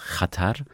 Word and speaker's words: Xatar: Ja, das ist Xatar, richtig spricht man das aus, Xatar, das heißt Gefahr.